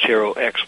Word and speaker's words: HeroX: Um, and I guess, Nidhi, when, when I first realized HeroX was the pronunciation, it made me think uh-huh.